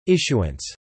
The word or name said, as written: issuance